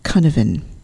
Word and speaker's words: Cynefin: it's spelt C-Y-N-E-F-I-N, which is pronounced Cunavan.